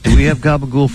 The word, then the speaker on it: gabagool: And the gabagool. – Right. – and you don't pronounce the last L and O, just gabagool.